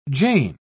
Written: Jane

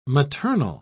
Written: maternal

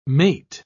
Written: mate

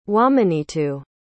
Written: wahmenitu

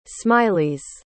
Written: smileys

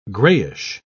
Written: grayish

